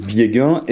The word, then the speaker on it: Biegun: alors oui, rectification, mon nom c'est Vieguin Et c'est marrant, que euh, vous le prononcez à l'allemande et euh, ben, ma femme est allemande.